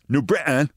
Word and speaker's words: New Britain: Let me say that correctly in the local vernacular. New Britain.